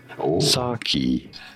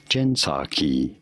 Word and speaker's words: Psaki: Psaki. The P is essentially silent here. Jen Psaki.